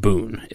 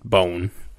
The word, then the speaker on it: Boon: If you're interested in the more old-style method of traditional lambics and gooses, our next producer here, it's spelled B-O-O-N. So you would think it's pronounced Boone. It's actually pronounced Bone, like B-O-N-E.